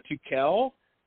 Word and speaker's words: Tukel: People normally put an R in the middle of the name, and they call it Turkel instead of Tukel.